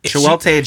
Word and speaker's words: Chiwetel: Did you say Shitwell? – Chitwell Ejiofor? – Chiwelte Ejiofor. – Ejiofor. – Yes, thank you.